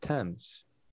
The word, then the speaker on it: Thames: It's pronounced Thames.